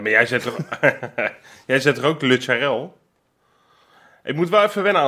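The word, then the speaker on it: Lutsharel: Het is niet Lutscharel, het is Lutscharel. – Ja, maar Freek, moet rijmen op Karel.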